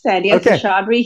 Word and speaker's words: Sandhya Seshadri: You pronounced my name great, Sandhya Seshadri here.